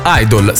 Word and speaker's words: idle: In questo contesto, però, era inteso come fannullone, Idol, scritto I-D-L-E, parola la cui pronuncia è la stessa di Idol, idolo.